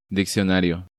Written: Diccionario

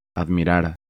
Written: Admirar

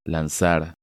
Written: Lanzar

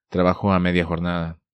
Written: Trabajo a media jornada

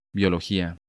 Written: Biologia

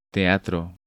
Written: Teatro

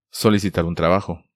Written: Solicitar un trabajo

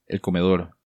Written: El Comedor

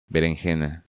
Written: Berenjena

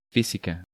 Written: Fisica

